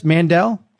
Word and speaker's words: Mandell: Did I say that right, Joyce Mandel? – That's right.